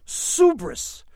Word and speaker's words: subris: The word is subris, which he notes rhymes with hubris.